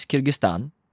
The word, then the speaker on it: Kyrgyzstan: So I think that for this one, the country is Kyrgyzstan.